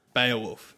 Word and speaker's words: Beowulf: It's pronounced Beowulf, Dave.